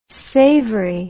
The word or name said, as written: savery